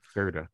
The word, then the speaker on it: Surtur: Surtur is that how you say it? Surtur. – Yep, Surtur,